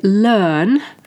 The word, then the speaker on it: learn: So, for example, a word like learn, L-E-A-R-N, where R is not pronounced.